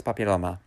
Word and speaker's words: papilloma: Papilloma is papilloma.